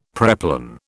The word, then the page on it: preplan: British English